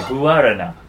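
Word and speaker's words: guaraná: Guarana. – It's not guar. – It's guarana. – Guarana. – Guarana. – Instead of making the U and the A one sound, you make two different vowel sounds.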